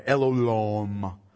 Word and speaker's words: El Olam: He worships God as the everlasting God, El Olam, O-L-A-M. But you pronounce it like there's a W in there, El Olam, okay?